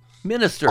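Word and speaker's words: MxNxSxTxR: So Minister, not Monster. Minister.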